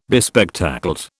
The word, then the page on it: bespectacled: British English